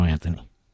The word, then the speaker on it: Anthony: The H is silent, you know, Anthony.